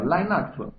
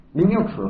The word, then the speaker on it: Linux: 呃，标准念法叫 Linux，呃，国内呢都念 Linux 对吧？这个它其实是基于这个 Linux 内核呢进行了这个开发。好，这个呃呃，这基于这样一个 Linux 内核进行开发，它对它做了一些包装。